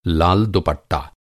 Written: लाल

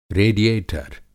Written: रेडिएटर

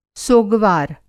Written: सोगवार

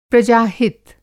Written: प्रजा-हित

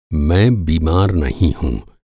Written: बीमार

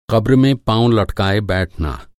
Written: क़ब्र

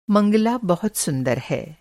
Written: मंगला

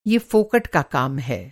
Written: फोकट

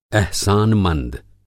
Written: अहसानमंद

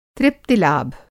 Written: तृप्तिलाभ

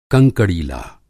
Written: कंकड़ीला

